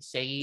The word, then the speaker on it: Shayi: I'm just going to say, like, when I was reading it, I wasn't expecting how things turned out in the end between, it's pronounced Shayi, right? Shayi. – Yes, that's Keisha. Right, Shayi. Uh-huh.